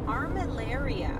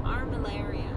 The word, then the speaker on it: Armillaria: Armillaria. – Like malaria. – With arm in front of it. – Armillaria. – Armillaria.